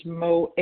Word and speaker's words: Mo: This is Mo H., and I spell it M-O.